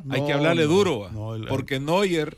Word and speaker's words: Neuer: O sea, es que le hubieras visto la cara a Neuer. Neuer, Epa, así es el apellido, así se pronuncia.